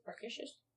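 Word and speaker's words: Märkisches: One is at the, oh no, how do you pronounce that I-S-C-H sound? – Well, there's less lisp than what you're doing. – Hey now! Don't mock me. Markisches? – Good enough.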